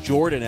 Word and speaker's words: Yordan: It's so funny, last night, Paul Sporer, of course, of FanGraphs, friend of mine, retweets a tweet from April of mine about how Yordan Alvarez wants to have his name pronounced Jordan Alvarez.